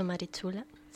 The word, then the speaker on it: Marichula: Lo he dicho bien, ¿verdad? ¿He dicho Marichula? – Sí.